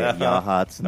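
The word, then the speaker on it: Yachats: I'm just going to try to do a yakety yak, but say Yahats, and that fell apart. It looks like it would be pronounced – it's like Y-A-C-H – maybe ATS, but it is in fact Yahats.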